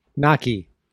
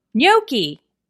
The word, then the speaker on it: gnocchi: I always pronounced it gnocchi, and then someone told me it was gnocchi, and I just believed them like a fool.